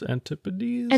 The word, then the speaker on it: antipodes: Uh, antipodes? Antipodes? – Antipodes. It's antipodes. Her brother's noontide with antipodes. Because it needed to rhyme with displease.